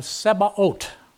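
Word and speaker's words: Sebaot (צבאות): The Lord of Sebaot, that's the Hebrew pronunciation.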